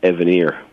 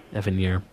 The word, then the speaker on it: Evanier: Before he comes on, you should know that his last name is actually pronounced Evanier. – Evanier.